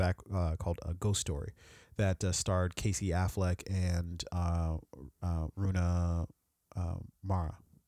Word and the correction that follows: Rooney Mara: Am I getting her name right? – Rooney Mara. Yeah.